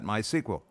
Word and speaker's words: MySQL: We're using a time-proven open-source relational database called MySQL. Again, sometimes people will pronounce that MySQL.